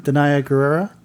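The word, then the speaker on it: Danai Gurira: Danaya Gurira.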